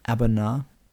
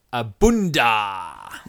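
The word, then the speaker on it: A'bunadh: Abuna. – Abunda. – Yeah, no, the D's towards the end.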